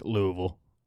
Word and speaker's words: Louisville: If you're from there, it's Louisville. Louisville. – Louisville. – But Louisville is the proper pronunciation.